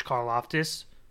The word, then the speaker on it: Karlaftis: Like, Jermaine Johnson and George Karlaftis both slid a lot.